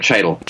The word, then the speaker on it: Chytil: And how do you pronounce, is it Philip Chittle? – I've always pronounced it Philip Chytil.